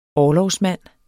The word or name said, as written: orlogsmand